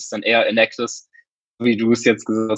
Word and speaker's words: Enactus: Wobei für Enactus gibt es so zwei Reden und Wir sagen immer Enactus, die deutsche Version.